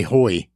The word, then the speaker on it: Hoy: Hey, I was able to pronounce Gary Howe. Gary Hoy.